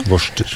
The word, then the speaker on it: Worcester: Worcestershaus, skal du si. – Worcester? – Ja. – Worcester.